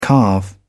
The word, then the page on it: calve: British English